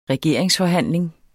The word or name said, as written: regeringsforhandling